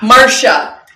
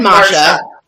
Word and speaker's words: Masha: Well, she said Masha. She said Masha, but the way that she was pronouncing it as an Australian. – It sounded like Marsha.